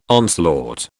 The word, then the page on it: onslaught: British English